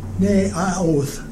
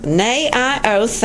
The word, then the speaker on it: Naioth: Sergeant Major, did I say that right? And told him all that Saul had done to him. And then he and Samuel went to Naioth. – Tell me again. – Naioth. – Naioth, thank you, at Ramah.